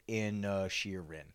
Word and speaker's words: Inisherin: I just Googled how to say Banshees of Inner Sheeran. – Okay. – And it literally, Banshees of Inisherin. – Good. Okay. – So we were saying it right.